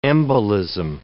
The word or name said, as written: embolism